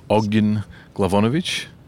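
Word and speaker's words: Ognjen Glavonić: but I'm very, very bad at pronouncing his name and have had people wince at me when I've tried to pronounce it, but it's Ognjen Glavonic.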